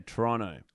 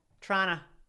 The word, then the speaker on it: Toronto: Well, they actually don't pronounce the second T. They like to say Toronto. – Toronto.